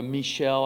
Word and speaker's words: Michal: We hear about Saul's daughter, who's David's wife, M-I-C-H-A-L, Michal, or Michelle. I heard one of the Bible professors pronounce her name. Michelle, we'll call her that.